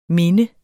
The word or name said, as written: minde